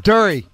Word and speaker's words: Derry: Brett is from Derry, New Hampshire.